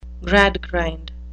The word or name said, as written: Gradgrind